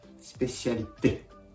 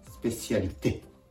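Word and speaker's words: spécialité: スペシャリテ、スペシャリテ。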